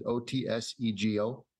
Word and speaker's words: Otsego: We live out in uh, uh, Otsego, Minnesota. I think that's how you pronounce it. O T S E G O. – Otsego. Mm-hmm.